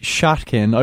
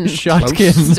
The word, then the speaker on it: Shatkin: They've been working production wise with Jesse Shatkin. I believe that's the correct pronunciation, guys. – Shatkin. Story checks out.